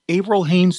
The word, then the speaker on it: Avril Haines: Avril Haines to be the Director of National Intelligence.